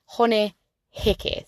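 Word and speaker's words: Hone Heke: A name famous for the protests around this time is Māori chief Honeheke. Honeheke, say it right.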